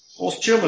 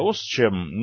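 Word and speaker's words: Ostchem: ОСЧЕМ, если я правильно ее называю. – ОСЧЕМ – это восточная химия. – Да, ОСЧЕМ. Ну, просто, да, это как бы по-немецки написано, поэтому я так затруднился с произношением.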